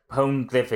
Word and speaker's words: poneglyph: Hey, Nico Robin says to the king, the poneglyph is down there. – Poneglyph. You said it right the first time you said it.